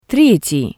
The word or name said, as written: Tretii